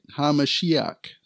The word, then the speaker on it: HaMashiach: And that's Yeshua, right? HaMashiach. That's how you say it, how the Hebrews would say it.